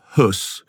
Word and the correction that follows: Höss: Well, it's Rudolf Hess, yes, because it's an O with an umlaut.